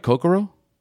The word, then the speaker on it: Kokoro: Did I say that right? Kokoro? – It's actually pronounced Kokoro, but we'll go with whatever.